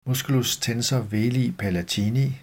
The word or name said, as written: m. tensor veli palatini